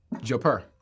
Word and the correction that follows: jodhpurs: Final answer. – No. – That is wrong. – What? How do you pronounce it, Maya? – Jodhpurs.